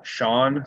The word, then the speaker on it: Shaun: So Shaun Sidey PT, at Shaun Sidey PT. So it's spelled S-H-A-U-N.